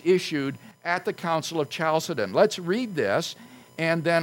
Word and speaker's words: Chalcedon: Well, the debate came to a head in the year 451 at the Council of Chalcedon. Now, I would write that name on the blackboard, but I'll simply spell it for you since I don't have a marker. Chalcedon, Chalcedon, Chalcedon.